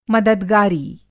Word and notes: मददगारी: Hindi

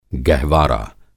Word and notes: गहवारा: Hindi

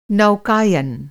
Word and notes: नौकायन: Hindi

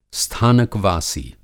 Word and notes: स्थानकवासी: Hindi

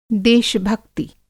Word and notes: देश-निकासित: Hindi

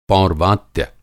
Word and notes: पौर्वात्य: Hindi

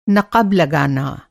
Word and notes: नक़ब: نقب; Hindi

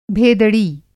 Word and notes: भेदड़ी: Hindi

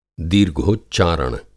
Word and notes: दीर्घोच्चारण: Hindi

